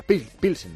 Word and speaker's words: Pilsen: En Pilsen, ¿no? Se pronuncia Pilsen. Bueno, a Pilsen, Pilsen.